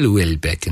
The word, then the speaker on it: Houellebecq: Houellebecq. That's very good pronunciation. It's not spelt like that. Just in case you want to Google or type anything into your search engines while you're listening to this, Houellebecq.